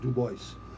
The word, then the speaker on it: Dubois: Dubois, I know it's not spelled, I know it's not pronounced Dubois. It's Dubois, right?